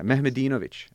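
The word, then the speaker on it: Mehmedinović: Semezdin Mehmedovic. – Okay. – Yeah, Mehmedinovic, actually.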